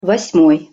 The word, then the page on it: vos-moj: Russian